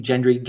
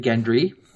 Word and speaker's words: Gendry: And then they managed to escape with a G, hard G, soft G, Gendry, Gendry.